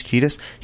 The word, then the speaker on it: Kiedis: Kiedis? The guy who's the singer for Chili Peppers? Kiedis.